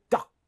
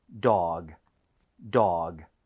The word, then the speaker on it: dog: 자, 그리고 발음할 때 너무 duck 하고 끝내지 마세요. – 네. – 이거는 dog이기 때문에 G로 끝납니다. 부드럽게 하셔야 돼요. – 네. – 자, 한번 해볼게요. dog, dog.